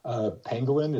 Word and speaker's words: Pangolin: God, did I pronounce that right? Pangolin? – Pangolin, it's a type of animal.